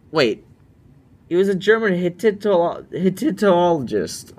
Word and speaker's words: Hittitologist: Hittitologist.